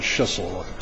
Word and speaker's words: Schissel: Petitioner John Schissel. Rhymes with whistle.